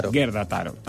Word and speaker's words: Gerda Taro: ¿Cómo se pronuncia esto? – Gerda Taro. – Así, sí.